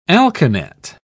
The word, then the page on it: alkanet: British English